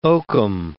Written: oakum

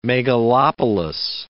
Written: megalopolis

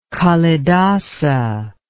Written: kalidasa

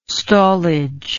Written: stallage

